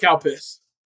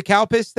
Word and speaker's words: Calpis: C-A-L-P-I-S. – Cow piss. – Cow piss. – Okay. – You literally cannot pronounce it without it sounding like cow piss.